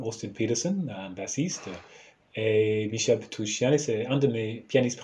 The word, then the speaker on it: Michel Petrucciani: Michel Petrucciani. – je le prononce bien, ça va Oui, oui, je pense.